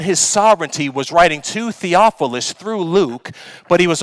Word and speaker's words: Theophilus: And these letters were written to a gentleman by the name of Theophilus. Theophilus is the pronunciation. First time I read it, I think I may have said like Snuffleupagus or something.